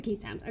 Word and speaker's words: Keeshond: Keeshond. It's the Keeshond. Okay, we're moving on. Which I think could be pronounced differently, but that is the way I learned it, and that's what we're going with.